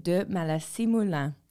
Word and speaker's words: de Maleissye-Melun: It is Chloé de Maleissye-Melun.